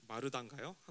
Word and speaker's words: 마르다: 왜 어, 마리아와 말사 마, 르다가요 한국 한국 발음은 마르다.